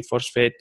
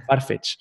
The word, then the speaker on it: Farfetch: Eh, aquí, bueno, teníamos una comparativa, por si queréis parar el vídeo, de todas las diapositivas que estoy pasando sobre Etsy, Forsfet, eh, joder, nunca sé pronunciarla, Farfet, no sé cómo se pronuncia esta empresa, pero cómo se pronuncia. Farfetch.